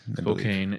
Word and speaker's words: Spokane: Up in, I never know how to pronounce it, Spokane or Spokane. – Spokane.